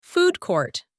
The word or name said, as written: food court